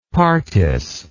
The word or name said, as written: partis